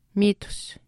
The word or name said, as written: miitus